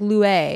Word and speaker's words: Luai: His name is spelled L-U-A-I. How would you pronounce that? – Lue? – Yeah, it's like Lue. – Lue. – Lue. And they live in Dubai. – Like Louis, but like Lue.